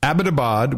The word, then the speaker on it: Abbottabad: Abbottabad, which is the pr- correct correct pronunciation.